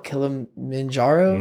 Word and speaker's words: Kilimanjaro: But he was training to climb Mount Kilimanjaro.